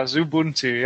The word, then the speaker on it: Xubuntu: That installs, it was, Xubuntu, Zubuntu. I don't know how to pronounce that. – Yeah, Zubuntu, yeah.